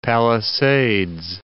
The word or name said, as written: Palisades